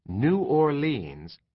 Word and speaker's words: New Orleans: The pronunciation New Orleans is just for song lyrics, rhyming as it does with beans, means, queens, and scenes.